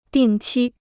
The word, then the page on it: 定期: dìng qī